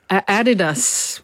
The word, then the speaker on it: Adidas: Adidas fits in with the stress pattern. It's the third last syllable, isn't it? Yeah.